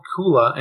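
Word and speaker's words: Koohlah: So it's called Kula, and it's spelled with a K-O-O-H-L-A-H.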